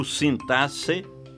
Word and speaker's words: sintaxe: Você pronuncia sintaxe ou sintasse? Que eu saiba, apenas o Aurélio abona ambas as formas. Prefiro acompanhar o Ais, Caldas Aulete e o Volpe, que registram apenas a pronúncia sintaxe.